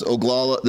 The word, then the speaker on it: Oglala: That's Oglala, the Hunkpapa Oglala Lakota tribe.